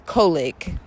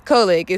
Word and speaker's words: collogue: And today's word is colic, which means dialect, conspire, or to talk privately, or to confer. So yeah, new word, guys, colic. It's spelled C-O-L-L-O-G-U-E. And I am saying it correctly because I played it to make sure I was saying it correctly.